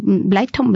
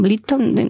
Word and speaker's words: Blyton: no sé cómo se pronuncia, Annie Blyton, Blyton, no, sí, el inglés no es lo mío. – Las dos están bien.